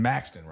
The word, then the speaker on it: Maxton: So you're down there in Maxton, right? Am I saying it wrong? – Yep. Nope. You said it right.